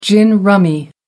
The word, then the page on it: gin rummy: American English